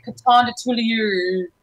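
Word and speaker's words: Coton de Tulear: Now, how do you pronounce the Coton de Tulier? – Honest, it's like my lips have to come out farther from my face. It's like Coton de Tulier.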